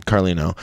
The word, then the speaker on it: Carlino: How do you say your last name? – Carlino. – I got it right.